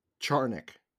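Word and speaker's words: Czarnik: Like they sent down Cernak, who could be Charnick is how you say his name apparently. – How is it? – Charnick.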